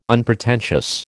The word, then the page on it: unpretentious: American English